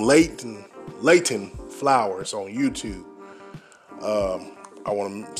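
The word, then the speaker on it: Leighton: His name is Layton Flowers. And I want to spell that name right. It's L-E-I-G-H-T-O-N. It's Layton and then Flowers, you know, F-L-O-W-E-R-S.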